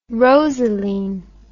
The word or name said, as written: rosaline